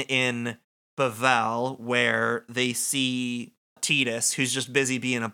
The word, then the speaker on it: Tidus: They say Tidus, and they pronounce it just like that. – The correct way.